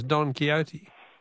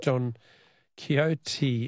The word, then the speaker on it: Don Quixote: Someone's correcting me in my pronunciation of Don Quixote. It's Don Quixote. Didn't I say that? Not Don Quixote, says Tegan from Heathcote.